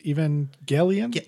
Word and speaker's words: Evangelion: Neil, have you ever watched Evangelion? – Yep, Hard G. You got it.